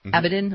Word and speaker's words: Abedin: Wiener and Abedin.